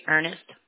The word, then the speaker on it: Earnest: Corey Ernest. – Ernest, okay. How do you spell it, the first name, please? I'm sorry. – C-O-R-Y. – And then Ernest is E-A-R-N-E-S-T? – Yes, ma'am.